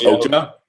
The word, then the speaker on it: Okja: How do you pronounce it? – Okja. – Yeah. Oh, it's Okja. – I think it's. Okja.